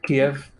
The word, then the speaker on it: Kyiv: Kyiv.